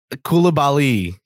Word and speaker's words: Koulibaly: Anyway, number seven, Bilal Koulibaly. Did I pronounce that correctly? Koulibaly. Yeah.